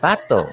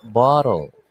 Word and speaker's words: bottle: No, it's not battle. It's bottle. Wow naman.